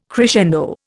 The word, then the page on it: crescendo: Indian